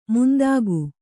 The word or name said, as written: ಮಂಥಿಕ